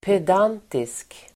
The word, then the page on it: pedantisk: ped'an:tisk